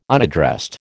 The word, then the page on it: unaddressed: American English